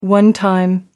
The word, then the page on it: one time: American English